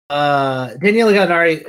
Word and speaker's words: Danilo Gallinari: Daniello Gallinare. I don't think it's pronounced like that. Daniele Gallinari is signing the Atlanta Hawks.